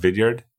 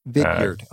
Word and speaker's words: Vidyard: Vidyard is another one that's Diggard? – Popular. And yeah, it's called Vidyard. – Vidyard. Okay. – I think V-I-D-Y-A-R-D.